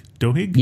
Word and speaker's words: Duhigg: I know Tony Robbins, but the Charles Duhigg? Duhigg? – Yeah. Yes.